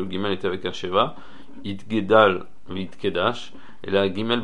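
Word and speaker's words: Itgadal (יתגדל): Il faut bien prononcer le guimel de Itgadal pour ne pas que ça s'entende Itkadal, qui est un langage de Oref, ça veut dire l'arrière de la tête.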